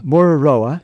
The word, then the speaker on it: Mururoa: the Mururoa. – Mururoa. – Mururoa. – Uh-huh. – It's like rural.